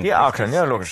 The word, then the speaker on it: Akren: Ah, wird schon mal anders. – Das wollte ich sagen. Wird anders ausgesprochen. Akren wird es natürlich ausgesprochen.